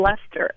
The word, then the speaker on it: Leicester: Our family name was Howard hyphen Lester, as in L-E-I-C-E-S-T-E-R, as in England.